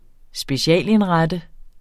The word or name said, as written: specialindrette